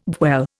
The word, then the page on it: dwell: Indian